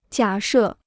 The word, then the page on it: 假设: jiǎ shè